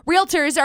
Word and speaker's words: realtor: If you listen to any of the commercials, realtors are a part of the National Association of Realtors. Not realtors are a part of the National Association of Realtors. No, realtor, realtor, realtor. Two syllables, realtor, okay?